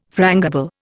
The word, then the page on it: frangible: Indian